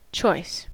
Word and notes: choice: American English

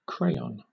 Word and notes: crayon: British English

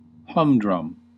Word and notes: humdrum: British English; American English

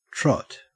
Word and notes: trot: Australian English

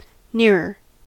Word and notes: nearer: American English

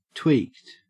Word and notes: tweaked: Australian English

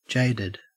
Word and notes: jaded: Australian English

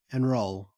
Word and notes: enrol: Australian English

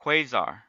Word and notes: quasar: American English

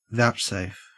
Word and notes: vouchsafe: Australian English

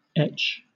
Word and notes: etch: British English

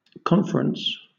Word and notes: conference: British English

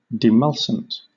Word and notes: demulcent: British English

